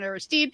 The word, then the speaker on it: Aristide: Haiti ends a three decades of military rule with the election of Jean Bertrand Aristide. Am I saying that right? – Aristide.